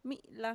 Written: mi'la

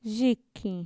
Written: yikin

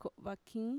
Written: ko'vakiin

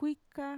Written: kuika

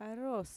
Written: aroos

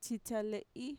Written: txitxale'i

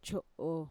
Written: cho'o

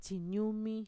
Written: txiñumi

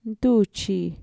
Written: ntuchi

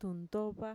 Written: xitxa

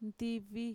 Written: ntivi

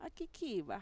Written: akikiiva

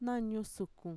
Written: nañusukun

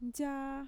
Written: ntxaa

